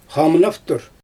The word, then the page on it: xamnaftur: Xyzyl